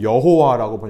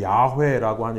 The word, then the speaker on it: 야훼: 그래서 이게 나중에 모음이 어떻게 됐는가를 몰라서 사람들이 한때는 여호와라고 번역을 했습니다. 근데 현대 신학에서는 여호와라는 이름이 정확한 게 아니라 야훼라고 하는 이름이 발음이 정확한 발음이라고 생각합니다. 야훼, 야훼.